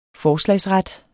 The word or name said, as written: forslagsret